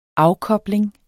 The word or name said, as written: afkobling